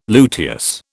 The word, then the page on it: luteous: British English